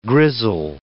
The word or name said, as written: grizzle